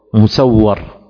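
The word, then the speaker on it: مُسَوَّر: وهكذا الثوري والتوزي، الثوري والتوزي، ومسور ومسور، مسور بكسر الميم مع إسكان السين، ومسور بضم الميم وفتح السين وتشديد الواو، مسور.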